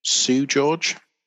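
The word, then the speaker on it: Seu Jorge: Sue George? – Yeah, I think that's right.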